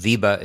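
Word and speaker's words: VEBA: I suppose VIBA is the right way to pronounce the acronym.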